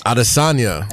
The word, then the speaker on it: Adesanya: How do you say the last name? – Adesanya. – Adesanya. – Yeah.